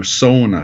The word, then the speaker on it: sauna: But you asked about the pronunciation, you know, in northern Minnesota, where there's a lot of Finlanders, you know, by heritage, they're very proud of the word sauna, S-A-U-N-A, and it's pronounced so, like S-O-W, sauna, sauna, you know, so it's an elongated vowel on the front end,